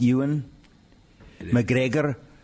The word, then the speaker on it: Ewan McGregor: And it's pronounced Ewan McGregor.